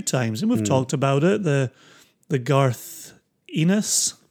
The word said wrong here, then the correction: Ennis: This is now the second year where you it's Ennis. – Ennis. – Yeah, it rhymes with penis.